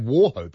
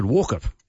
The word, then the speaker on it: Wauchope: 1-3-1-8-7-3. This Arkansas and Kansas thing reminded me of something in Australia. We have Wauchope, of course, on the New South Wales mid-north coast.